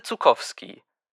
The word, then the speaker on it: Zuckowski: Zukowski. – Wie schreibt man das? Bitte buchstabiere. – Ich heiße Zukowski. Das schreibt man Z-U-C-K-O-W-S-K-I.